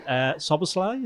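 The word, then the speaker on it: Szoboszlai: So they got in McAllister, and this is my first attempt to pronounce him. Soboslai, is it? – Perfect. Yeah, I think you've done very well.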